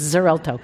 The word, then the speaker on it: Xarelto: It's Zalexto. It's one of those things where they marketing wanted to have an X and a Z because those make medicines very effective. Mm. So they have an X and a Z in the same name, and I can't pronounce it. But anyway, it's Xarelto.